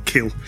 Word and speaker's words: Khil: Edward Kill with, I think, should I say his name, K-H-I-L, Kill, with this song, I Am Glad Because I Am Finally Returning Back Home.